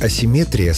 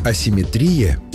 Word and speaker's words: асимметрия: Вариант асимметрия словарями не поддерживается и считается ошибочным. Так что в русском языке симметрия есть, а вот асимметрии нет. Запомнить легко. Понятие «симметрия» — это когда все ровно и по центру, даже ударение стоит по центру слова. А вот асимметрия — уже с нарушением баланса, поэтому и ударение съезжает к концу.